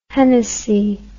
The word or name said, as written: hennessy